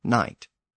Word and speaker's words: knight: But when that sound disappeared, it left English with quite a few words with a silent K at the beginning, including knight.